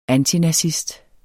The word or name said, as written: antinazist